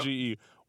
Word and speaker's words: newdge: You end most of your Instagram posts with the word nuge. Am I pronouncing this right? – N-E-W-D-G-E. Yes, nuge, bro.